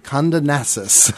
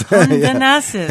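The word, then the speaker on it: Kondonassis: And he performs with harpist Yolanda Conundant. – Condonassus. – Condonassus.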